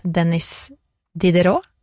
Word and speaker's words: Denis Diderot: Og så har jeg lest, nå må du rette meg på uttalen her, Heine, men det er en opplysningsfilosof som heter Denis Diderot. – Ja. – Er det riktig? – Bra.